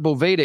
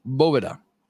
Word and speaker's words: Boveda: It is not Boveda, it is Boveda.